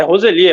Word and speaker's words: Roseli: Com gols de Roseli ou Roseli, acho que depende, né, da pronúncia que a mãe dele. – Mano, é Roseli, Com gols de... É Roseli, é Roseli.